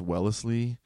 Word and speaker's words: Wellesley: She did a commencement speech at, I believe it's pronounced Wellesley University.